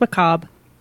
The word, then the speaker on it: macabre: Just macabre. – Oh, it's a silent R-E.